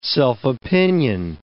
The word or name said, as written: self-opinion